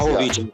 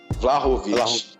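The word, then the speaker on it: Vlahović: É que eu não sei falar bem o nome dele, Vlaz, Vlasic. Você, você sabe melhor pronunciar. Vlahovic. – Vlahovic.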